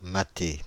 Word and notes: leer: [lɪəʳ]; [lɪr]

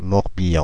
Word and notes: Morbihan: IPA: [mɔʁ.bi.ɑ̃]; French; Paris

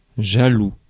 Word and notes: jaloux: France; /ʒa.lu/

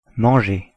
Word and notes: manger: France

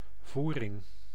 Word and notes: voering: [ˈvuːrɪŋ]

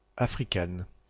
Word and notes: Africaine: France (Aquitaine); /a.fʁi.kɛn/

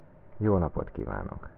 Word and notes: good day: American English; /ɡʊd deɪ/

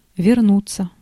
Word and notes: вернуться: /vʲɪrˈnut͡sə/

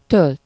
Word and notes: gieten: /ɣi:.tə:/

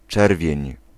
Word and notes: rouge: [ʁuʒ]; France

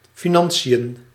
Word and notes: financiën: /fi.ˈnɑn.si.jə(n)/

